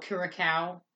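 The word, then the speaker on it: Curaçao: How do you pronounce that ship's name? – Oh, Curacao.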